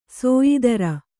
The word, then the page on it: ಸಯ್ತಿರ್: saytir